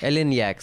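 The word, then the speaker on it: Eleniak: Ellen Lack. – I think that's how you pronounce it. – Ellen Yack. – Ellen Yack, sorry, whatever.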